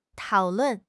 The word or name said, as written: tao3 lun4